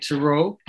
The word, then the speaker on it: Tureaud: He was named Lawrence Turaut. That's spelled the French way, T-U-R-E-A-U-D. And I'm pronouncing it Turaut.